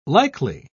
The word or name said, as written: likely